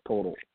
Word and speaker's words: Poeltl: Jakob Poeltl.